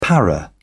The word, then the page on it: para: British English